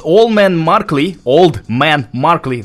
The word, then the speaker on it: Old Man Markley: We just heard two songs by the band called Old Man Markley.